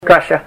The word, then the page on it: kasha: Ninilchik Russian